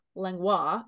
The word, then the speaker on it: Langlois: And his name is Chris Langlois, otherwise pronounced Langlois if you're fancy in French.